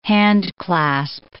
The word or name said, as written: handclasp